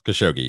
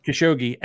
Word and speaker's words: Khashoggi: According to the author, we know they killed Jamal Khashoggi. Is that how you say his name? – Khashoggi, yes. – Khashoggi.